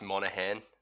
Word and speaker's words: Monaghan: It's Monaghan.